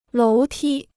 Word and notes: 楼梯: lóu tī

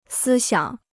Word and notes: 思想: sī xiǎng

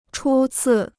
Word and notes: 初次: chū cì

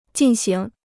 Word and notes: 进行: jìn xíng

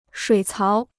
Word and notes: 水槽: shuǐ cáo

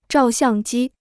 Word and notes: 照相机: zhào xiàng jī

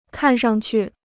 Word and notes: 看上去: kàn shang qu

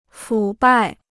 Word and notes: 腐败: fǔ bài

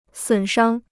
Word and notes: 损伤: sǔn shāng

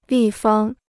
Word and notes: 立方: lì fāng

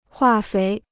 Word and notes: 化肥: huà féi

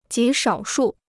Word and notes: 极少数: jí shǎo shù

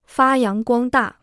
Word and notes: 发扬光大: fā yáng guāng dà

